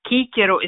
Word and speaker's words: Cicero: När vi läser latin på A-kursen, vi säger Kikero istället för Cicero.